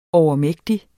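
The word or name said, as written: overmægtig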